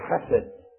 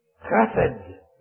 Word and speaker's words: chesed: Now, the Hebrew word chesed. You have a little saliva in your mouth to say it right. The Lord's loving kindness, chesed, which is the Old Testament parallel to the New Testament mercy, that we are not consumed.